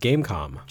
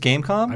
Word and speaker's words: Game.com: I believe it is just Gamecom. – Just Gamecom? – I believe so. The dot is silent.